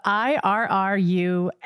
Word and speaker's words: irrumatio: It is spelled IRRUMATIO, Irrumatio.